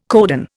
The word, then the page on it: codon: Indian